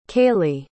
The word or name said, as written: kayleigh